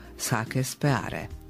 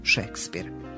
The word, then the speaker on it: Shakespeare: Naprimjer, tako možemo doslovno prenijeti i prepisati englesko sake speare. Kada neku riječ iz izvornog pisma prenesemo u latinicu, zamjenjujući engleske foneme latiničkim i vodeći računa o izgovoru, onda imamo posla sa transkripcijom. U našem primjeru, primjenjujući transkripciju, izgovaramo Shakespeare. Transkripcija je prenošenje znakova iz jednog pisma u drugo, pri čemu se vodi računa o izgovoru glasovno, morfološko oblik i značenjsko prilagođavanje.